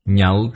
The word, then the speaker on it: nhạo: Nhạo thuyết vô ngại trí. Chữ này là đọc thanh khứ. Đọc là nhạo.